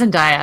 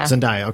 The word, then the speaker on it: Zendaya: I think it's Zendaya. – Zendaya.